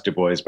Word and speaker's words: Du Bois: So, yeah, well, Du Bois, and it's pronounced Du Bois, by the way.